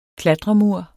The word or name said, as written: klatremur